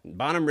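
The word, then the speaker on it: Bonhomme Richard: The blaze was reported before 9 a.m., and the USS Bonham Richard, is that how you say it? Bonham? – Mm-hmm. – Yes, yeah, nailed it. Bonham Richard.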